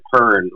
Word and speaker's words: Hearn: No, it's Hearn. H-E-A-R-N. So like Hearn, like it rhymes with burn. – Okay. – Or churn.